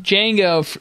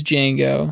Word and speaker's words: Django: And he says, my name's Django. And he says, how do you spell it? And he says, D-J-A-N-G-O. The D is silent.